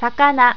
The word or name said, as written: sakana